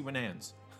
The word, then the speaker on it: Winans: It's CeCe Winanis, I think. Winan, Winans, Winans, Winans, that's it, CeCe Winans.